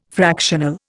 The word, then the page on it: fractional: Indian